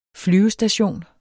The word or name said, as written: flyvestation